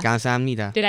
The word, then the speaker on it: 감사합니다: 감사합니다. – 감사합니다. – Did I say that right? – Yep.